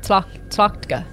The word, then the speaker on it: Tlachtga: Chlok, yeah. – Yeah, Tlachtga. – It's, it's like a, you pronounce the first, but you have to do a. – Gotta do a little almost skip. like a click.